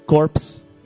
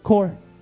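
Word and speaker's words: Corps: Y no es Corpse, es Core. Punto.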